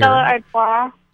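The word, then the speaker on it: Stella Artois: How do you say that? It's the beer. – Stella Ardois. – Yeah, the beer.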